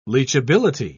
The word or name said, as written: leachability